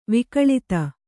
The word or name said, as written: ವಿಕಳತೆ